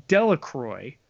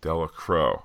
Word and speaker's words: Delacroix: As someone who is from New Orleans and fishes in, I'm going to say it wrong here first because he's going to fix it, Delacroix, pretty cool to see that mentioned in an MCU show. Just wanted to help you out with some Louisiana pronunciation. Delacroix is how it's said.